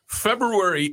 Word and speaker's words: February: February 8th, and I said that properly. – Yes, you did.